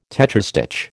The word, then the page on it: tetrastich: American English